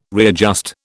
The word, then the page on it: readjust: British English